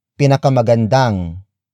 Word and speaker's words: pinakamagandang: Pinakamagandang dalaga. The first word is pinakamagandang. Pinakamagandang. Pinakamagandang. Pinakamagandang is spelled as P-I-N-A-K-A-M-A-G-A-N-D-A-N-G.